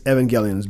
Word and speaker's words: Evangelion: Yeah, it's Neon Genesis Evangelion.